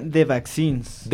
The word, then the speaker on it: The Vaccines: Se pronuncia The Vaccines. – The Vaccines. Hagan esa como K en medio porque son dos S. Entonces es The Vaccines.